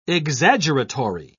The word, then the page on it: exaggeratory: British English